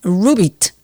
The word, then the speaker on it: Roubit: Hoy te hablaré de Rubit, así se llama, se escribe R-O-U-B-I-T.